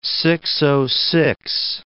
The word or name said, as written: six-o-six